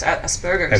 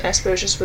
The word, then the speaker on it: Asperger's: It's pronounced with a hard G. – Oh, really? – Yeah. – Okay. – So it's Asperger's. – Asperger's. – Okay, because I've heard it both ways, and the person I know pronounces it Asperger's with a soft G.